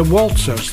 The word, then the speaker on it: Waltzers: In September 1970, Kevin Keegan met his future wife, Jean Woodhouse, on the Walters at Doncaster Fair. The Waltzers, that was, in case I did mispronounce it.